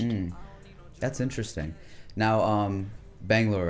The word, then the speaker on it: Bangalore: Yeah, that's about right. Like, I mean, it's they're pronounced a couple of different ways. So some people say Bengaluru, some people say Bangalore. So it just depends.